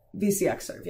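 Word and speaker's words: VcXsrv: You can use Xming and you can use VNCSERV or VXCSERV. Let me make sure that I'm actually saying that right. VCXSERV, yes.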